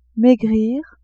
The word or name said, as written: maigrir